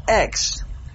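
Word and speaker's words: ek (ἐκ): Well, that word from is a preposition, and the preposition is the word ek, or it could be pronounced ex.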